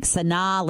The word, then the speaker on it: Sanaa: Well, I just looked it up and it says you pronounce it like Sanaa, like Sinatra, like Sanaa.